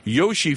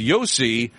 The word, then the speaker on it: Josi: He says, Don, you've been calling Roman Josi Yoshi for weeks. It's pronounced Yossi.